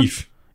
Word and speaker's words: Yves: wird der Ives oder Ives ausgesprochen? – Yves. Yves. Yves. Sicher? Yves. Ja, sicher. Wie Yves Saint Laurent, dieser.